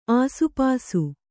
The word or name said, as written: ಆಸುಪಾಸು